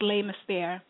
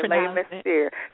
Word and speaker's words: Les Mystères: Is it Les Mystères? Like the mystery? It's Les Mystères. – Okay, Les Mystères.